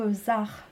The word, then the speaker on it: Zaj: Tu as parlé du groupe ZAR, donc ça s'écrit ZAJ. Oui. Hein. C'est on a parlé la dernière fois de ce J espagnol.